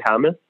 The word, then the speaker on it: James: It's very weird for me to say James because I always want to say Hamas. – So you pronounce really it weird. How you're supposed to pronounce it in your country, all right? – James.